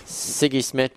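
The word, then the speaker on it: Sigi: I don't know how you say it. – Siggy Schmidt.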